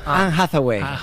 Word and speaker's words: Anne Hathaway: Anne Hathaway.